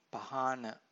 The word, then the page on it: pahana: Pali